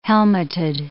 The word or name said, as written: helmeted